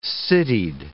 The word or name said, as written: citied